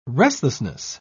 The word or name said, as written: restlessness